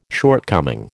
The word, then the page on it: shortcoming: American English